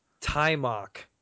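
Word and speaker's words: Taimak: So, how do you pronounce the guy's name properly again? – Timok. – Timok. – Timok. Yes, sir.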